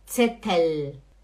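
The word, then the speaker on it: Zettel: Por favor, o L é L, L, L, e não U, não é Gabel, não é Löffel, não é Tzetel, é Gabel, Löffel, Tzetel, hum?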